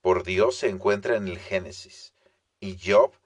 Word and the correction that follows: Job: describe su, bueno, Job, perdón, lo pronuncié en inglés, ni modo.